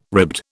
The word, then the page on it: ribbed: British English